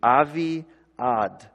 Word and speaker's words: Avi-Ad: So if you would spell it in our English kind of letters, it would be A-V-I and then a dash, A-D. Avi-Ad.